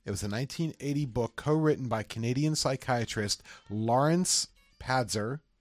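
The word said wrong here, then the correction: Pazder: It's pronounced Pastor. I'm sorry.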